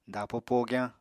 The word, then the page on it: dapo pogyañ: Apatani